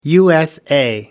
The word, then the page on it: usa: American English